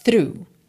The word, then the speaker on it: through: Essa palavra T-H-R-O-U-G-H é pronunciada through, through, ok?